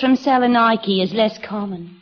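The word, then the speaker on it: Salonika: Mine's at Salonike. – Excuse me, Mrs. Haggerty, but the correct pronunciation is Salonike.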